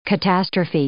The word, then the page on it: catastrophe: noun